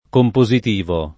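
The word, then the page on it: compositivo: italiano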